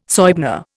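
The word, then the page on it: soigné: Indian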